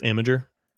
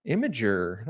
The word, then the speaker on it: Imgur: It's over on Imgur. I never know how to say this website name. – I think it's Imgur. – Imgur. That makes way more sense.